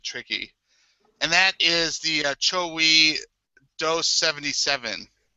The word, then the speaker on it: Chogui: Chowi. And it's spelled C-H-O-G-U-I.